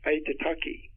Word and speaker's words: Aitutaki: It's the top of a volcano, and it sunk, the little tops of it sticking above the water, and it's Aitutaki. And it's spelled A-I-T-U-T-A-K-I. I have to think about it. Aitutaki.